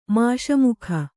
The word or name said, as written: ಮುಸುಮೊಗ